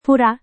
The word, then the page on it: pura: Indonesian